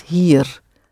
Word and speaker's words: hier: Het is niet hier. That's it. Roll that little R at the end, just that little bit.